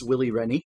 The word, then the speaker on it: Willie Rennie: Okay, rest in peace, Willie Rennie. – No. Don't say his name like that. You need to say his name like you're going down a slide.